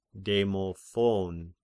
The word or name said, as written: Demophon